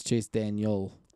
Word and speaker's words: Daniel: It's Chase Daniel. – Chase Daniel. – You said Chase Daniels.